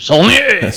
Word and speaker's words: Saulnier: I think it's Saulnier. Okay, I was going for the French pronunciation. Saulnier.